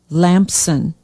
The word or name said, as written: Lampson